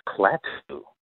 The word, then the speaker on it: Klaatu: As any science fiction fan knows, it would be pronounced Klaatu.